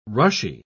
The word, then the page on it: rushy: British English